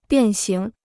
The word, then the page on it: 变形: biàn xíng